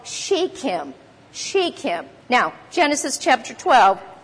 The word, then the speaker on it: Shechem: The King James says Sychem. There's different ways to pronounce this, but that's it. To the place of Shechem, unto the plain of Moreh.